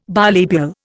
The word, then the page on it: bilabial: Indian